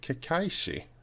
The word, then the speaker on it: Kekkaishi: Och vi börjar med Lotta här som ville ta upp Kekkaishi, eller hur man uttalar det. – Mm, det var faktiskt ganska bra.